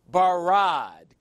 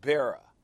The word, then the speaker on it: bara: In the beginning, God, that's four, barad, created, the Hebrew word bara, B-A-R-A. You had to throw an H on it so people wouldn't pronounce it Bera.